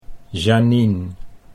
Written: Janine

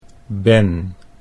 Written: Ben

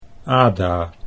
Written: Ada

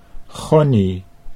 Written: Gonnie

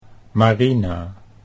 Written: Marina

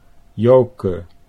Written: Joke

